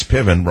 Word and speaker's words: Piven: It's in my book, Trickle Up Poverty, on page 53, and I talk about Richard Andrew Cloward, rhymes with coward, and Frances Fox Piven, rhymes with driven.